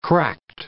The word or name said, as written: cracked